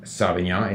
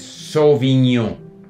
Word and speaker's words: Sauvignon: It's not Sauvignon, it's Sauvignon. So, we have the word, we have that O sound in English.